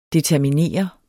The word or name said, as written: determineret